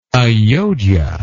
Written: Ajodhya

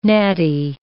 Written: natty

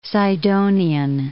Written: Sidonian

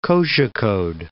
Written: Kozhikode

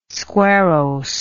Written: squarrose

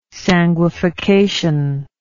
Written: sanguification

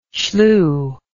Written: Shluh

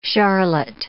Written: charlotte

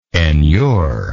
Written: enurement

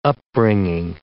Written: upbringing

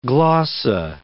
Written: glossa

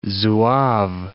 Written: Zouave

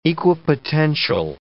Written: equipotential

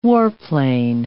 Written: warplane

